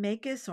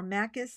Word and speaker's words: Makis: This is from Dr. William Makis, or Makis, sorry, I mispronounced his name.